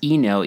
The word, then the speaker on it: Eno: It was advertised as like a backpacking hammock made by, I think it's pronounced Eno, E-N-O.